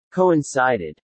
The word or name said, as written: coincided